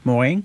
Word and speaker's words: Mohring: Do I say it right, Morin? – Mm-hmm. – And very good pronunciation.